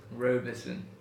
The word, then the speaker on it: Robeson: Robeson.